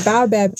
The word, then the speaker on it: baobab: I just want to talk real briefly about your graphic design, the things that you're putting out as a baobab ink, I believe. Forgive me if I'm saying that incorrectly. – No, that's right. The baobab tree.